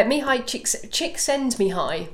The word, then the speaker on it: Csikszentmihalyi: Uh, Mihaly Csikszentmihalyi.